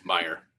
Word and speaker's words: Meijer: And obviously we've seen like just a huge, just the retail market, meaning like your Targets, your Walmarts, your major, how do you say that? – Meijer. – Meijer, there we go.